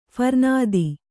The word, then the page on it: ಫರ್ನಾದಿ: kannada